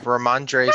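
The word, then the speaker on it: Rhamondre: Yeah, Ramondre Stevenson.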